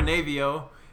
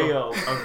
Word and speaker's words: Aveo: So I drive a Navio. And it's a little Aveo.